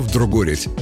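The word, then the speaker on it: вдругорядь: Это сокращение от старинного наречия «вдргореть». Да, именно так, с неочевидным ударением на «о». Обозначало «вдргореть» повторение некоего действия несколько раз.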